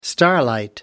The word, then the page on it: starlight: American English